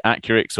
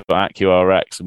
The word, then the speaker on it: Accurx: we do get asked a lot, is it Accurx or AccurX? And we don't really mark, we say Accurx, but you can say it how you want. I think apparently there's a bit of a north-south divide on pronunciation.